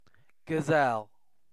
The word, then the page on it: gazelle: British English